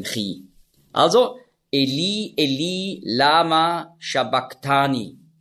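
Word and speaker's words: sabachthani: Und das CH im Griechischen, das ist ja ein Buchstabe, das Chi, das hat man ausgesprochen so wie ein C hinten im Gaumen. K. Also nicht Shabach, sondern Shabak.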